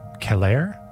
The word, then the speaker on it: Celer: And right now we're hearing from the 2009 Students of Decay release, Poulain by Keller, C-E-L-E-R. My Latin is telling me to pronounce that Keller.